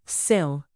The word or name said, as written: sill